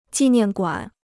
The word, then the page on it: 纪念馆: jì niàn guǎn